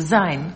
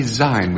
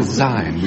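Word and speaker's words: Auld Lang Syne: Well, it's from Old Lang Syne. – Syne. Syne. You mustn't say Syne. We get letters from Scotland.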